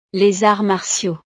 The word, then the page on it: les arts martiaux: frances